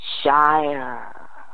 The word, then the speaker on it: Shire: Warsan, and it's spelled W-A-R-S-A-N. So it may be Warsan, you know. – Mm-hmm. – Yada. – You got to use your full tongue to say it. Shire. Shire. Shire.